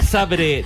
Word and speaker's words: Zabrze: Górnik Zabrze de Polonia 2, Mónaco 3, ganando el equipo monegasco. ¿Por qué sabe que es Zabrze? ¿Por qué no? ¿Por qué pronuncias Zabrze? – Porque es así.